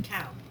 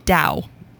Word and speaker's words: Tao: T-A-O. That's pronounced Dow. Dow. Yeah. Dow with a, yeah, like it's got a D.